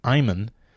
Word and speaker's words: Eyman: And the author, Scott Eyman, I believe that's the way he's pronounced his name, E-Y-M-A-N, is coming on the podcast.